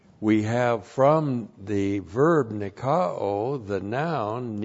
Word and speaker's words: nikao: It is a substantival participle of the verb nikao. N-I-K-A long O. And nikao means to be victorious, to conquer.